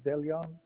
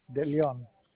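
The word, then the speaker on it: De Leon: Last name is Deleon, D-E, capital L-E-O-N. – May you say your last name again for me, sir? – D-E, space, capital L-E-O-N. Deleon. – Emmanuel? – Yes. – M-A-N-U-E-L? – Yes.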